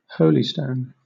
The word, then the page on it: holystone: British English